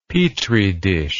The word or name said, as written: petridish